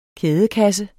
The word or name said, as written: kædekasse